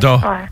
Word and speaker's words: Dawes: Mais c'est ça, ça, se prononce dans le, le ES, ça se prononce pas.